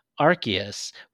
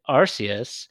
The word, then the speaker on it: Arceus: was the Pokemon Arceus, which I've always been pronouncing it Arceus, and apparently it is Arceus, which is the Pokemon god, but this is Pokemon Legends Arceus.